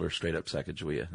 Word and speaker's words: Sacagawea: And in Shoshone, Sacaga is bird and Wea is woman. So it's Sacagawea, not Sacagawea.